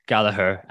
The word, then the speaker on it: Gallagher: Douglas Luiz, not David Luiz, Anthony, Douglas Luiz, Bowen, Gallagher, and Ronaldo. Someone on YouTube actually told me it's a hard G, remonstrating that it's a hard G, and he'd apparently asked the man himself.